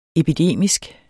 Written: epidemisk

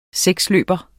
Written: seksløber

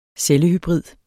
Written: cellehybrid

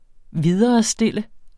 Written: VIDERESTILLE

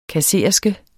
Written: kassererske

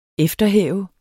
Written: efterhæve

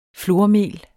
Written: flormel

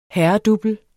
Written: herredouble

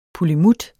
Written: pullimut

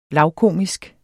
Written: lavkomisk

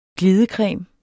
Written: glidecreme

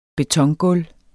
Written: betongulv

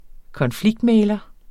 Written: konfliktmægler

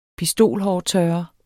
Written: pistolhårtørrer

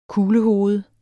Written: kuglehoved

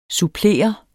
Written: supplere

